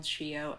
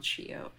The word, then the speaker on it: Shio: And on one of my passports, my name is spelled Shio, S-I-O. And then the other one, it's spelled Shio, S-H-I-O.